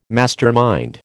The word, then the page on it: mastermind: American English